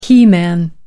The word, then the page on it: he man: American English